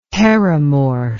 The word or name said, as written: perimorph